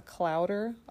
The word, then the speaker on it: clowder: A group of cats is called a clowder. – Oh, I didn't know that one. A clowder? A right. Clowder of cats? – Sounds like chowder. – It does rhyme with chowder.